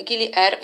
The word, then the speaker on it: Gili Air: Ogólnie wśród tych trzech wysp Gili Air, właściwie mogłam powiedzieć, że to ma się Gili Air, bo w języku Bahasa właśnie tak się wymawia Air, no ale wszyscy tak to zangielszczają na R i R w ogóle to jest śmieszne, bo to znaczy woda, a nie powietrze, więc to jest wyspa wodna.